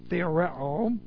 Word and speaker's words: theoreo: It's the present active indicative of theoreo. And so let's get this word on the board. T-H-E, long O, R-E, long O.